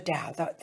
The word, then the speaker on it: Tao: And it's called The Tao Made Easy, T-A-O, pronounced Tao, The Tao Made Easy.